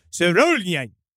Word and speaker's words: Sørjulen: It was called Sørjulen. That definitely was what it was called. – Yeah. – That's how you pronounce it.